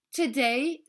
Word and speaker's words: today: Today se prononce today en insistant sur le A à la fin.